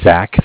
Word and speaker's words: Zach: My name is Zach, spelled Z-A-C-H. – Okay. – I'm with the Bank of Cardiff. Cardiff is spelled C-A-R-D-I-F-F.